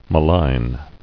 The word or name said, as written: Maligner